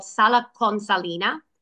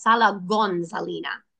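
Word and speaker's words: Sala Consilina: But because obviously he spoke with a bit of an accent and there was dialect, the town itself is called Sala Consalina, but he always pronounced it as Sala Gonzalina.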